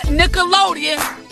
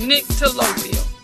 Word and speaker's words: nyctalopia: It is spelled N-Y-C-T-A-L-O-P-I-A, nyctalopia. Not Nickelodeon. Not Nickelodeon, but nyctalopia.